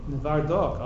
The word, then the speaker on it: Novardok: Before he was bebrois, he was born in bebrois, and the Aruch HaShulchan was rov in his city, spelled N-O-V-A-R-D-O-K. Will somebody please pronounce that for me? – Nevardok. – Oh, you got it right. Shani bo ke'es Nevardok.